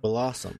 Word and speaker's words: Bellossom: What is Bellossom? Bellossom. I've said it right once, and now I can't do it. – Bellossom.